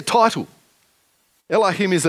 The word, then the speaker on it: Elohim: But in Hebrew, the word God here is Elohim. And it's pronounced pretty much the same in, English as in Hebrew without perhaps so much spit.